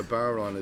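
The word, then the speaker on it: bodhrán: Let me explain what a Baron is. It's spelled B-O-D-H-R-I-N, but it's spelled Baron.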